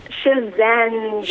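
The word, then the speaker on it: Schisandra: How did you say? Shisandra? Shisandra? – Shazandra. – Shazandra. Okay. Like Shazam.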